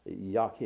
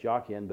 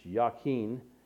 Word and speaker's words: Jachin: It's pronounced Jachin. It looks like Jachin, but it's Jachin.